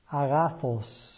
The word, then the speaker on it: agathos: Agathos is the Greek, pronounced somewhat like that, agathos.